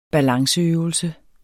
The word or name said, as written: balanceøvelse